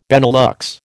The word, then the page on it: Benelux: American English